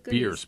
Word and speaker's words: Bierce: Ambrose Bierce. B-I-E-R-C-E. – Bierce rhymes with fierce.